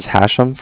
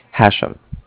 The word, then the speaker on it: Hashem: Yeah, this is Hashem from Cardiff. – From who? – Cardiff. – What's that? – It's a bank. – Okay, and what was your name again? – Hashem. – Spell that for me. – That's H-A-S-H-E-M, as in mother at the end.